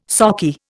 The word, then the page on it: sockeye: Indian